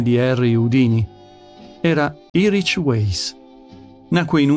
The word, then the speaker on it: Houdini: Uno di questi uomini è stato Harry Houdini, e non con l'accento sulla I finale, come erroneamente si pronuncia.